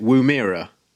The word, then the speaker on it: Woomera: Woomera.